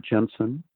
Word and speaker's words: Jensen: Well, if people put my name, Robert Jensen, into Google, and if you spell it right, it's J-E-N-S-E-N.